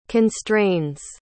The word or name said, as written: constrains